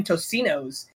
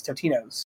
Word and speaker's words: Totino's: Tostino's. And they messaged him like, no, dude, you got to say it right. It's Totino's.